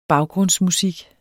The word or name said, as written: baggrundsmusik